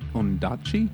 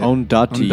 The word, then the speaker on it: Ondaatje: And this week we are reading The English Patient by Michael Odanch- Ondaatje. I had to, I looked up how to say it. Ondaatje, yeah.